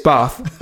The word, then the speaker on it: Bath: and if you listen to his stream talking about it, he, he had forgotten how to pronounce it all of a sudden. It's Bath.